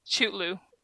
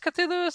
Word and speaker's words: Cthulhu: I guess Cthulhu is the right way to say it,